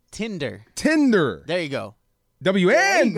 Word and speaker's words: Tinder: You understand how to pronounce Tinder now. – Tender, tender. I'm s- tender, nope. – tender, tender steak, tender t- the app. Tinder. What? – Ten. Can you say ten? – Ten.